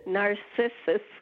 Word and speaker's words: Narcissus: Also, how do you say the name of this plant? It's a hoot trying to Google it and then finding narcissist come up every time. – Narcissus.